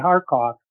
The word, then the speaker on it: Kharkov: And when I say Kharkov, that is how it is pronounced, Russian. Kharkiv is Ukrainian.